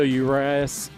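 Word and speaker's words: Urías: Julio Urias.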